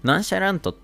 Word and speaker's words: nonchalant: で、まああと、ナンシャラントって読み方は、あのー、アメリカ、英語、 US 読みです。